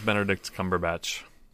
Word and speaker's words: Cumberbatch: You said it correctly. – I know. His name is not incorrect. One I say wrong. – Yes, it is. – Oh, that's right. Aren't you supposed to say his name wrong? I don't remember. Yes. You've told me before. – It's Bonderdick Snodgrubatch.